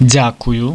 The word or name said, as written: dziakuju